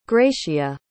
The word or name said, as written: gratia